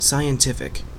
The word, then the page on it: scientific: /ˌsaɪənˈtɪfɪk/